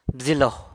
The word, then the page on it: bzilo: San Lucas Quiaviní